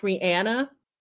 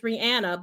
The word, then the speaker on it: Rihanna: It's not only because, did you know her name is supposed to be pronounced Rihanna? Yeah, it's supposed to be Rihanna. There was an interviewer that asked her that one time. And she clarified that it is Rihanna, but girl, we don't say that.